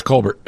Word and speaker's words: Colbert: All right, and Colbert and Kimmel. – Yeah. – And it's not Colbert, it's Colbert.